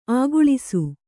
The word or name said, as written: ಅಗಳಿಸು